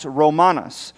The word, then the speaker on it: ROMANVS: It's spelled this way, and it's pronounced Romanus.